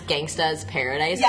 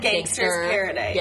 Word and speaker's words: Gangsta's Paradise: Did you hear about that guy who lost $3,200 because he didn't pronounce Gangsta's Paradise correctly? – Yeah, because he said Gangster's Paradise.